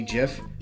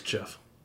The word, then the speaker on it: GIF: It's still pronounced Jif. – It's not. – It is. – It's awesome. Pronounced Jif and Gif. I will. – It's pronounced Jife. – Jife. Well, now you're just Jife or Gife.